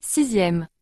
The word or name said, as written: 6e